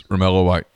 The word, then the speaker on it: Romello White: Yes, Romello White.